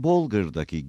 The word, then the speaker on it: Bolger: Örneğin Bolger'daki G harfi C sesi verir ve Matom Metum diye okunur.